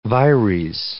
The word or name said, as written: vires